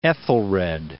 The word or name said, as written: Ethelred II